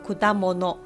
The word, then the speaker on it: 果物: 那水果在现在的日文汉字写作果物，念作 kudamono。